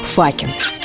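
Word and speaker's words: Fucking: А прямо сейчас мне прям-таки не терпится послать вам открытку из города Факен. Да-да, его название пишется и произносится именно так, как вы сейчас подумали.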